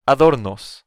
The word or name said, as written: adornos